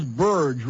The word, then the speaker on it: Burge: And here's a letter from Charles Burge, rhymes with purge in Pompano Beach.